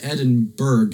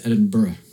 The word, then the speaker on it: Edinburgh: It's not Edinburgh, it's Edinburgh. – Ah, yes. – Because it's actually spelled G-H at the end.